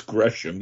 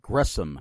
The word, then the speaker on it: Gresham: It's Gresham. You got it right. It's actually Gresham. – That's what it was. Gresham.